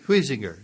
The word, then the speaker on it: Huizinga: Huizinga, is that? – Huizinga, yeah. H-U-I-Z-E-N-G-A.